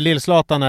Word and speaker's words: Lill-Zlatan: Nej, för det är Lill-Zlatan är då en tjej som bor hos sin... Så är det Lill-Zlatan eller Lill-Flatan? – Jag sa Lill-Zlatan. Jag uttalade det som Ralf Edström.